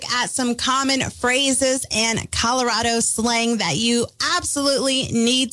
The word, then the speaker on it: Colorado: And I'm very guilty of this because I say Colorado.